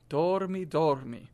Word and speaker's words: dormi: And notice I don't pronounce the R's like R. I don't go dormi dormi. We don't want dormi dormi. We want flip your tongue just a little bit. Dormi dormi. Say it again.